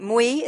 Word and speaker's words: Mui: And Emily, am I pronouncing your name right? Mui, is that the correct pronunciation? – Yes, it's Mui.